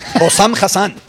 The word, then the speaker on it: Hossam Hassan: Hossam Hassan.